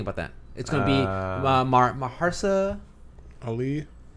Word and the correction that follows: Mahershala Ali: Is it Maharsa Ali? That's how you say it? – Mahershala Ali.